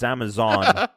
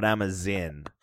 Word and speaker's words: Amazon: It's Amazon, not Amazin.